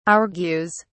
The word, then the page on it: argues: British English